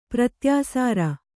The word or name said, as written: ಪ್ರತ್ಯಾಸಾರ